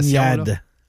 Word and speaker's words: Zibanejad: Le pire, c'est que ça a du sens, comme prononciation. Zibanejad.